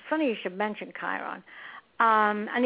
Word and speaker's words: Chiron: How do you pronounce it? – Chiron. – Chiron, okay.